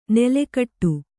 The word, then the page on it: ನೆಲೆಗಟ್ಟು: neletaṭṭu